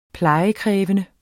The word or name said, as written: uplejet